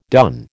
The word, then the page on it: done: American English